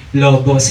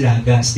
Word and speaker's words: logos: sinasabi lagi, logos, hindi daw po. Ang salitang pronunciation talaga ng logos is lagas.